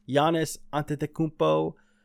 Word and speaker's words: Antetokounmpo: Giannis Antetokounmpo won it. – Wow, I was just impressed you said his name correctly. Like, no stuttering, no nothing.